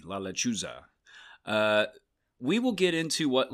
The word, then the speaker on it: La Lechuza: You're going a little heavy on your S's there. It's with a Z, not an S. – La Lechuza.